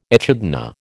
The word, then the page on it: echidna: American English